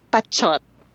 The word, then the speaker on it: Pachot: It's actually Pachot. – No way! – Yes way. And this is very genius of me. It really is spelled P-A-C-H-O-T, and it's Pachot. But since I liked how people were addressing me as Pachot, I put a letter T after P-A. So I spelled my name P-A-T-C-H-O-T.